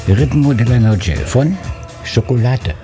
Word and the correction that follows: Chocolate: Ja, Chocolate. – Ach so, so wird das ausgesprochen.